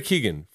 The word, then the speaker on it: Keoghan: Your nominees are Brendan Gleeson from The Banshees of Inisherin, Brian Tyree Henry from Causeway, Judd Hirsch from The Fablemans, Barry Keoghan. Did I say that right? – It might be. Keegan. – Barry Keegan from The Banshees of Inisherin.